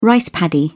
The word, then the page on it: rice paddy: British English